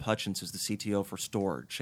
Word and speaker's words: Storj: So, you know, in reading articles about Storj, and for everyone listening, that is spelled S-T-O-R-J, like storage, and we'll get into, you know, why that makes sense.